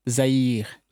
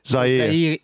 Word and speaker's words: Zaire: Zaire. – Yes. – Zaire in French.